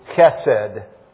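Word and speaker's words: chesed (חסד): C-H-E-S-E-D, chesed. It has kind of a, you have to kind of get the in there. Otherwise, it's spelled H-E-S-E-D, chesed. But now you got to get the C-H in there, chesed.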